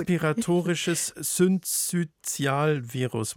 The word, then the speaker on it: Respiratorisches Syncytialvirus: Respiratorisches Syncytialvirus.